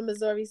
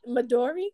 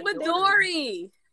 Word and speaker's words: Midori: That's because we were saying it wrong. It's Midori? Is it Midori? – It's Midori.